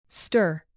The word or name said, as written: stir